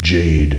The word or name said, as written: Jade